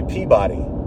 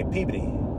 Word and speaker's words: Peabody: I'm not going to Peabody, as they call it. A normal human would say Peabody, but not in Massachusetts, not here. The Boston folk, they don't say Peabody. They say Peabody. Peabody. Like P-E-E-B-D. That's how you pronounce the thing that is, in fact, spelled P-E-A-B-O-D-Y, Peabody.